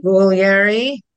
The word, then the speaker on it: Guglieri: Guglieri. The G is silent, but there is a G.